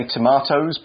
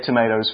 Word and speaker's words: tomatoes: Quick note, in the UK we would say tomatoes, but I'll say it tomatoes for you guys. one of those items was mushrooms. – Mm-hmm. – Let's spell that M-U-S-H-R-O-O-M.